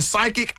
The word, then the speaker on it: psychic: Right, because when we see psychic, it's spelled P-S-Y, right? – I couldn't tell you. – Yes, yeah, this, yep. – So the P is silent.